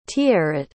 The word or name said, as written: tiaret